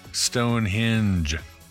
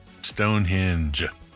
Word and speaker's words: Stonehenge: And second of all, man, Stonehenge, rhymes with door hinge and orange, Stonehenge.